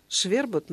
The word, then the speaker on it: швербот: А ударение, кстати, вот я сказала, швербот на первый слог, да? – Так-то и словарь.